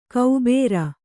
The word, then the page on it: ಕೌಬೇರ: Kannada